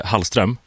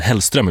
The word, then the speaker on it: Hallström: Jag och vår kära kollega Michelle Hallström, som många blandar ihop och skulle bruka säga Hellström ibland.